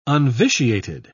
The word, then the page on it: unvitiated: British English